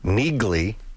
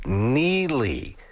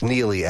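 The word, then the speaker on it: Neagley: I mentioned before the break that I had not seen the show called what looked like Neigley. But as folks on the text line have let me know, it's Neely. It's N-E-A-G-L-E-Y, but it's L-E-Y, but it's pronounced Neely as in Cam Neely. So there you go. T-I-L, the things I learned.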